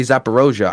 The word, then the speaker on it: Zaporozhye: When I go to Zaporozhye, I say Zaporozhye. I don't say it the Ukrainian language way.